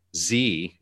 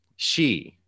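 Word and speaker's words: Xi: The J is pronounced like a J, or the leader of China is not Z, it's she, like S-H-E.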